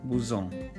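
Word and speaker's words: Bezons: Yes, I'm in Paris right now in a town called Bouzon. It's pronounced as Bouzon, but it is, the spelling is B-E-Z-O-N.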